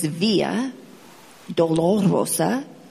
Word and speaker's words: Via Dolorosa: But his take on it was a lot of people mispronounce the name of that road. It's Via Dolorosa.